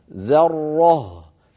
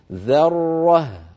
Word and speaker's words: ذرة: وأيضا بعد انتهائنا من الراء المشددة لا نترك فمنا أيضا مفتوحا هكذا، فنقول مثلا ذرة فتخرج الهاء حينئذ مفخمة، وهذا أمر لا تفعله العرب ولم يقل لنا في قراءة القرآن. لابد من ترقيق الذال والهاء وتبيين الراء المفخمة بينهما هكذا ذرة ذرة.